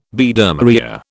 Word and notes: Biedermeier: British English

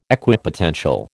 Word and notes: Equipotential: American English

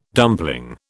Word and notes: dumpling: British English

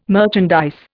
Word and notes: merchandise: Indian

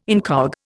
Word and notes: incog: Indian

